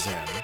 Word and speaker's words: Xen: So the record is it's spelled X-E-N, pronounced Zen.